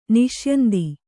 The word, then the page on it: ನಿಷ್ಯಂದಿ: niṣyandi